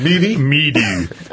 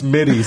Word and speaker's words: MIDI: Meaty. – Take your meaty keyboard. – They didn't teach him that it's pronounced midi, so it's actually really funny.